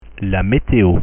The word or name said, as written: la meteo